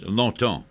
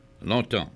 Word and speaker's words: longtemps: Is longtemps. – Yes. – We use that. Longtemps. – And how would you spell that? – L-O-N-G-T-E-M-P-S.